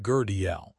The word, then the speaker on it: Gödel: If you are into the limits of human logic, look up Kurt Gödel, pronounced Gurdiel, and his first incompleteness theorem.